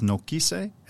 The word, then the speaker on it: Nokise: So it's Nukise.